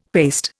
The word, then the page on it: paste: Indian